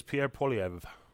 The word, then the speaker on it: Poilievre: Pierre Poilievre or something? – It's Pierre Poilievre.